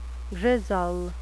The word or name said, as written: grizzle